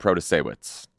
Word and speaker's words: Protasiewicz: Protasiewicz. Still remember how to say the name. – We both nailed it.